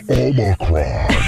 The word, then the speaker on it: Omicron: I am Omicron.